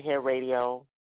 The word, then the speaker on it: hairadio: We pronounce it Hair Radio, and the reason that we spell it is because it only has one R.